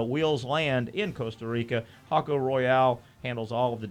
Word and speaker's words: Jaco: But it's spelled J-A-C-O, the name of the city. The beach town in Costa Rica is Hocko.